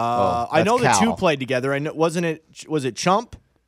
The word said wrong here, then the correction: Champ: and then Morikawa? – No. Champ. – Sorry, I pronounced it wrong.